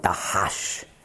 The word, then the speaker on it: Dachasch: Und Dachasch, oder altebräisch ausgesprochen, Dachasch, entspricht im Arabischen Du Hass.